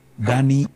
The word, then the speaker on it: Dani: Entonces, Dani Del Dani con I latina.